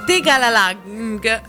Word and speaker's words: Tegalalang: Tegalalang è conosciuta, infatti la pronuncia va bene, non mi hai preso molto bene, non sono più in Francia.